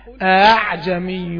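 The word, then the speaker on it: أأعجمي: أعجمي يعني بتنطق الهمزة الثانية بين الهمزة وألف المد تاء التسهيل بتنطق الهمزة الثانية في كلمة أعجمي بتنطق الهمزة الثانية مسهلة بين الهمزة وألف المد تحتاج طبعا لتدريب تقول أعجمي وعربي.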